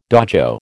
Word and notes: dojo: American English

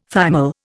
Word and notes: thymol: Indian

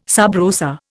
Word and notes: sub rosa: Indian

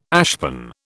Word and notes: ashpan: British English

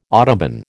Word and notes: autobahn: American English

